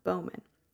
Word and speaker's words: Bauman: A journalist in 2014 attempted to identify Bowman. Of course, we only have a last name, and it is spelled the German way, so B-A-U-M-A-N, Bowman.